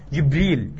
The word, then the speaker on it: جبريل: وأحيانا إيه بالهمزة جبرائيل، وأحيانا بغير همزة جبريل جبرائيل، أكثر من 10 وجوه.